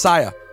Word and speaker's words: Sia: It's Sia. It's not Sia. I'm an idiot. – Is it? How you spell it? – I got to figure this out before the Grammys on Sunday, guys. It's Sia. I've always read her name wrong. – How do you spell it? – S-I-A.